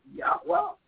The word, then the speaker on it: Yahweh: His name is YHWH. And how you properly pronounce it, Yahweh.